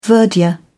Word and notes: verdure: British English

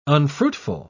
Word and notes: unfruitful: British English

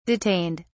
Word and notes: detained: British English

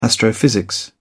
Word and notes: astrophysics: British English

